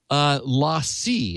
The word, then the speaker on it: LaCie: And then LACIE, is that how you pronounce it? L-A-C-I-E.